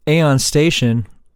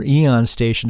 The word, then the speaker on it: Aeon Station: who is now in the band Aeon Station, or Eon Station, depending on how you're going to pronounce that, and Eon Station. I say Aeon Station because I used to like that show, that cartoon Aeon Flux, and that's how they spelt it and pronounced it, so there.